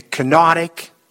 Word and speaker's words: kenotic: kenotic and the triumphant Christ.